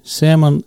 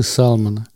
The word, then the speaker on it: salmon: Salmon – это то, что многие читают как salmon. L не читается в слове salmon, это, кстати, лосось. Читается salmon, хотя пишется salmon, а читается salmon.